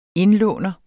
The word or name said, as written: indlåner